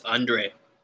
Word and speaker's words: Andriy: And then Andriy Yarmolenko. I hope I said that right. – That's Andre. – Andriy Yarmolenko.